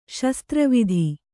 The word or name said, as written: ಶಸ್ತ್ರವಿದ